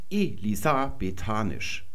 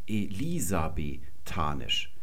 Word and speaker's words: elisabethanisch: Und dann kann ich diesmal den dritten Trocheus auch noch vollenden und hätte die Betonung Elisa betanisch. Und wir sehen, das stimmt nicht. Wir würden nämlich sagen elisabethanisch. Sehen das offenkundig, das können wir mal nach rechts ins Nichts verschieben, hier zwei unbetonte Silben sind. Die Betonung liegt auf dem Li und da vorne auf dem E, die ist auch unbetont.